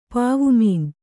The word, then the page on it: ಪಾವುಮೀನ್: pāvu mīn